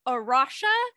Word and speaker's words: Arocha: I believe Arosha is how it's pronounced. It is spelled A-R-O-C-H-A,